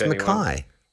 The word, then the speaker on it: Mackay: spelt like my name, and then they say it incorrectly. That's the only time I usually correct anyone. Oh, of course, Mackay.